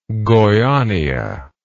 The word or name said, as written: goiania